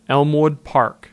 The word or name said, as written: Elmwood Park